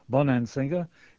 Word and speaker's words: Bonansinga: Bonansinger. If the name confuses you, it's spelled B-O-N-A-N-S-I-N-G-A. I asked you before we were saying it beautifully.